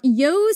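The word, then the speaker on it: Io: The people attending the feast would shout, "Yo, Saturnalia!" That's spelled I-O, Saturnalia. And it's pronounced yo, right? – Yep, it's pronounced yo.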